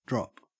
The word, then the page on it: drop: Australian English